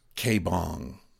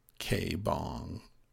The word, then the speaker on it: K Bong: The band is K Bong spelled like it sounds K Bong, living in a dream.